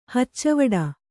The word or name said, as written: ಹಚ್ಚವಡ